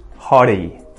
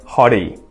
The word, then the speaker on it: hottie: If you want to say it like an Aussie, use that T flap, hottie, hottie.